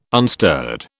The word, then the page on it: unstirred: British English